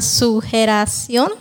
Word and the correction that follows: sugerencia: Sugerencia. – Sugerencia, perdón, siempre lo digo mal.